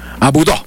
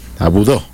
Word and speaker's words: Aboudou: Es medio complicada la pronunciación del francés Djamili Dini Aboudou. – Aboudou. – Aboudou.